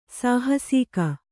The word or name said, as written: ಸಾಹಸಿಕೆ